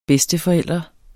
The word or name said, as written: bedsteforælder